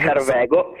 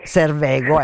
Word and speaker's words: Servego: Ser- Servego. – Servego, ecco, le, cioè, non è proprio il mio come, eh, come pronuncia, Servego, che è il modo eh, di dire eh, localmente gatto selvatico.